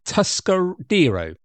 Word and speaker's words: Tuscadero: She was Leva Tuscarido, Tuscadero. There we go. Get it pronounced correctly.